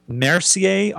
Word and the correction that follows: Messier: objects? – Oh, uh, yeah, Messier, objects. Messier, yeah.